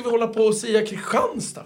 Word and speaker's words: Kristianstad: Hur uttalar de? Kristianstad, säger de. Det heter Kristianstad.